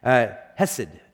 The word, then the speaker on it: hesed: I said it wrong last service. I just said hesed, and Tim's like, no, that's not right. Hesed.